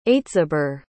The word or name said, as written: aitziber